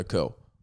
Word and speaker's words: coup: Some people will say January 6th was an attempt at a coup. I wouldn't. The coup within a coup. By the way, it's spelled C-O-U-P. In America, we should just spell it C-O-O. Coup.